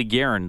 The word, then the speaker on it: Garyn: It's spelled different, but it's named after Billy Garen. This is kind of cool. The spelling is Garyn, so like Gary with an N, but it's Garen.